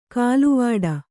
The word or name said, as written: ಖಲ್ವಾಟ